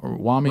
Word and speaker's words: Ramaswamy: Is it Ramaswamy? – Ramaswamy. – Ramaswamy. – Yeah, Ramaswamy. Some good things Who has gone up.